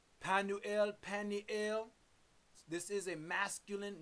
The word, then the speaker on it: Penuel: She's the daughter of Penuel. Penuel. Let's hear the pronunciation. – Strong's G 5323. Penuel.